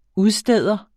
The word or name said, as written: udsteder